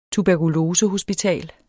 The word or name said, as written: Tuberkulose